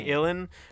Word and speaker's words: uilleann: And apparently Illan, which is spelled U-I-L-L-E-A-N-N, is just the word for elbow.